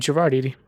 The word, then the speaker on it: Gerard Eady: Just my name, Gerard Eady.